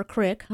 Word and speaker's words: creek: Which UK auto manufacturer was started by a former opera singer, was named after a creek or crick, depending on how you pronounce that word, near...